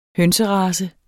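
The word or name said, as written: hønserace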